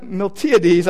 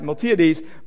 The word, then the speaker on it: Miltiades: And one of the Greek generals, um, Miltiad, Miltiades, I knew I wasn't going to pronounce that right, Miltiades,